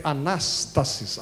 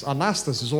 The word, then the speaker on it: anastasis: A palavra ressurreição em grego é anastasis, anastasis ou anastasis, dependendo da pronúncia, que faz lembrar Anastácia como um nome.